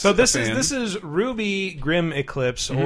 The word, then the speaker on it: RWBY: or as those of you who aren't familiar with the anime would probably pronounce it, seeing it on Steam or PSN, RWBY Grim Eclipse, because it looks like a big acronym.